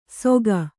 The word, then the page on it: ಸೊಕ: kannada